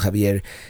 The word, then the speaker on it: Xavier: It's spelled Xavier, but it's pronounced Javier, and it's a beautiful mission.